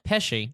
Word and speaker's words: Pesci: One day, friend and fellow Jersey boy, Joe, how do you say it? I can't say the last name. – Pesci. – Thank you.